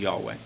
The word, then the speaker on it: Yahweh: And just to keep things simple as we go through the rest of the sermon, I'll be using the pronunciation as Yahweh.